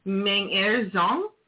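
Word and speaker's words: Meng'er Zhang: So, Meng-Er Zhang? – Okay. – Is that how you say her name? – Yeah.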